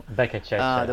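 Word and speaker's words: Beccacece: And finally, we have Elche, which really performed well when they were already in Segunda with Bekacheque as the manager. Or is Bekaseche? I don't really know. – Bekacheche. – The way to spell it. Bekacheche, okay.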